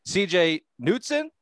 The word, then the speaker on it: CJ Knudsen: The senior vice president of the Vermont Lake Monsters, CJ Knudsen.